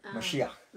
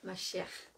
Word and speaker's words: Mashiach: How do you say Messiah in Mashiach? – Mashiach.